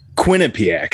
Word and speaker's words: Quinnipiac: He attended, hopefully I can say this right, Quinnipiac University. – Quinnipiac, right? – Quinnipiac. Is that how you say it from the East Coast? – I think it's Quinnipiac. It's Quinnipiac. There you go.